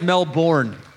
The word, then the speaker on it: Melbourne: They pronounce it Melbourne.